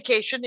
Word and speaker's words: Kashian: And it's, I said Stay Kashian, but the joke is Stay Kashian, like vacation people.